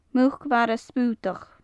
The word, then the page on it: muc-mhara sputach: gaelic